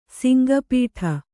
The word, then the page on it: ಸಿಂಗಪೀಠ: singa pīṭha